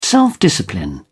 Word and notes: self-discipline: British English

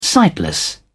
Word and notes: sightless: British English

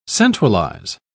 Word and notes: centralise: British English

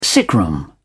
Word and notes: sickroom: British English

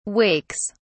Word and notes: wakes: British English

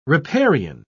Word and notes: riparian: British English

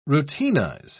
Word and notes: routinize: British English

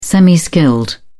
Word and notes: semi skilled: British English

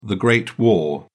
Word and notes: great war: British English

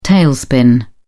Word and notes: tailspin: British English

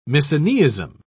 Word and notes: misoneism: British English

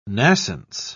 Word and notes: nascence: British English

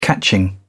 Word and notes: catching: British English

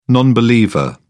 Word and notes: non believer: British English

